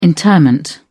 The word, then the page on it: interment: British English